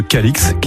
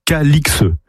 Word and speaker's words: Calix: Le nom était autrefois prononcé Cali, comme le montrent diverses formes anciennes du XIIIe au XVIIIe siècle, mais l'orthographe aberrante Calix a entraîné la prononciation actuelle Calix. Voilà pourquoi nous prononçons Calix depuis plus de 300 ans. – Voilà, on devrait prononcer le viaduc de Cali.